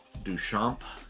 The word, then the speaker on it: Duchamps: The names are Marcus Cantunel, Beatrix Duchamp, D-U-C-H-A-M-P-S. The S is silent.